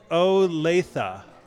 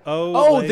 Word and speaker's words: Olathe: Anne says, Olathe. – Olathe.